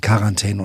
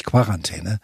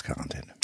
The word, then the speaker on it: Quarantäne: sondern aus dem Französischen von quarante. Und deswegen eben Quarantäne und nicht Quarantäne. Wenn einer unbedingt Quarantäne sagen muss oder will, kann er das gerne tun, aber die korrekte offizielle Aussprache ist Quarantäne.